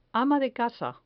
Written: ama de casa

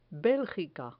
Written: belgica

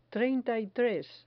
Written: treinta y tres

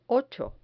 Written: ocho

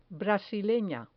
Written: brasilena

